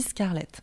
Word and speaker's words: Scarlett: Donc, Scarlett, je voulais pas le faire à l'anglaise ni à l'américaine. Il fallait que ça reste bah, voilà, un peu indo-french entre, les deux j'ai vraiment, on a essayé avec toute l'équipe de, le prononcer à la française.